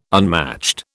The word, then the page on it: unmatched: British English